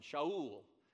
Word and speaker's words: Shaul: Saul is actually pronounced with an SH sound, Shaul. And the Greeks didn't know how to make a sh sound.